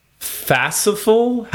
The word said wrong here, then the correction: Fastolf: I say Fastolf. – Fastolf. Okay, Fastolf. That's better.